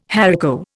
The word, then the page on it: haricot: Indian